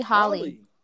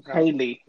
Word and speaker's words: Halle: It's actually Holly. – Chloe and Holly. – Holly, but you want to spell it like? Oh, it's Haley.